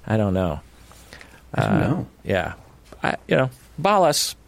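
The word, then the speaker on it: Balázs: I have no idea if I'm pronouncing Patron Balas's name correctly, but Balas. How do you spell it? – B-A-L, so Bal.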